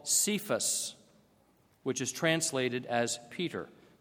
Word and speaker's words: Kepha: Aramaic has only one word for rock, and that word is Cephas, or sometimes pronounced Kepha.